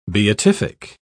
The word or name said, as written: beatific